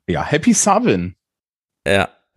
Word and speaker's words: Samhain: Savin, das ist der keltische Name dessen, was dann später mal Halloween geworden ist. Aha. Äh, wenn das Samhain steht, das heißt eigentlich Savin, weil das ja. keltisch ausgesprochen wird.